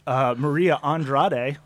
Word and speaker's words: Maria Andrade: We have a very special guest, Maria Andrade.